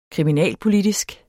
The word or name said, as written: kriminalpolitisk